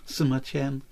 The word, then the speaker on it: Sima Qian: Sima Qian.